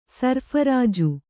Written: ಸರ್ಫರಾಜು

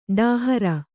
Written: ಡೋಹರ

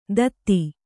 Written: ದಧಿ

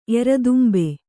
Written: ಎರದುಂಬೆ

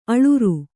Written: ಅಳುರ್

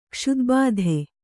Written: ಕ್ಷುದ್ಬಾಧೆ

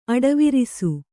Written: ಅಡವರಿಸು

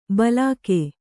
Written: ಬಲಾಕೆ

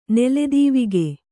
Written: ನೆಲೆದೀವಿಗೆ

